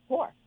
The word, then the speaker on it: Hoar: Whore.